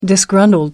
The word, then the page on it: disgruntled: American English